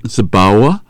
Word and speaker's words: Zabawa: Is that Zabawa? – Zabawa. Yes. Yeah, so W is pronounced as a V.